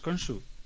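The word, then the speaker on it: Khonsu: ¿No es Konsu? Sí, Konsu. Konsu. Sí, es, con J, se escribe Honsu.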